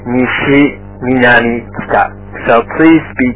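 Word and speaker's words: nishi (西): The next is the west is pronounced nishi, nishi.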